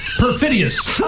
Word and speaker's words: perfidious: Let me correct you, my mispronouncing friend. You know, it's perfidious. – Oh, perfidious.